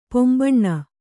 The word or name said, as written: ಪೊಂಬಣ್ಣ